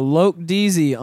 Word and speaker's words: Loc Deezy: Loke Deezy on SoundCloud. It's spelled L-O-C space D-E-E-Z-Y for you motherfuckers who don't know how to take what I said and put it in your search bar.